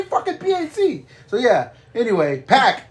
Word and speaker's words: PAC: How do you pronounce Neville's name? – Pack. – Pack. Like a six-pack. – Yeah. – Okay. Yeah. – I always want to say Pac because of Tupac. So like an X-Pac.